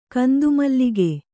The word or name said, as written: ಕಂದುಮಲ್ಲಿಗೆ